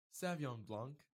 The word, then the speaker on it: Sauvignon Blanc: Sauvignon Blanc.